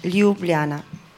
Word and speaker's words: Ljubljana: It's Ljubljana, Ljubljana.